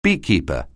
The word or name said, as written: beekeeper